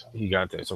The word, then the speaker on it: Gigantes: Gigantes are, or Gigantes, I don't know exactly how that's pronounced, Gigantes.